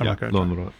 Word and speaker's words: Lönnrot: But one of the people who gets, you know, caught up in the folklore movement is a guy named Elias Lönnrot, or Ilya. I'm not sure how to pronounce it in Finnish. – Lönnrot.